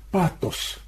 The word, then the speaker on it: пафос: Патос Иногда говорят пафос, там греческий звук такой на грани ТФ.